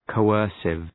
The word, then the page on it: coercive: kəʋ’ɜ:rsıv